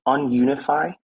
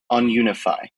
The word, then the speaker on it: UnUniFi: It's just Ununify, and it's a play on the word to decentralize, so to ununify.